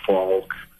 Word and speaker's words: Falk: If they would go on Facebook and just type in Dale Falk, and my last name is spelled F A L K, I've got stuff kind of plastered all over the place.